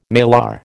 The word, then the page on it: malar: American English